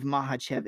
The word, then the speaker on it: Makhachev: I hope you're just as excited to listen to my breakdown on Volkanovski versus Makhachev, or Makhachev, however you want to say it. But I believe Makhachev is the correct pronunciation, baby.